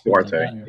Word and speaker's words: Duarte: They added Chris Duarte. Duarte? I don't know if I'm saying that right. They lost Duarte, yeah.